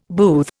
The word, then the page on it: booth: Indian